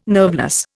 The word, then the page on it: nerveless: Indian